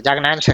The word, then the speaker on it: Jack Nance: No lo he dicho mal, ¿no? Jack Nance. Jack Nance, vale, vale.